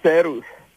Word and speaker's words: Seru: My name was Serus. – Your name was what? – My former name was Seru. It's spelled as S-E-R-U.